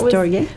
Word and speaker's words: storge: If I say it correctly, storge, is that how we decided that storge.